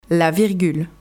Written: La virgule

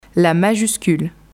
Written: La majuscule

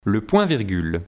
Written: Le point virgule

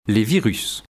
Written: Les virus

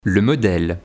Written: le modele

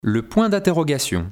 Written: le point d interrogation

